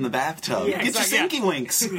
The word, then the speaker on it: Sienkiewicz: Bill Sienkiewicz, which is how you pronounce his name. – Yes. – Is Sienkiewicz, which I learned when I worked at Wizard, because that was like one of the first things, like, how do you pronounce this guy's last name? Because it is Sienkiewicz is, I think, how I pronounced it when I was like a little kid. – Sienkiewicz? – Yeah. – Because sounds like a game. – Yeah. Sienkiewicz.